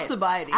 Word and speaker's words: Alcibiades: And can you please say the name, because I've already forgotten how to say it. Say it again. I can never say it. – Alcibiades. – Alcibiades.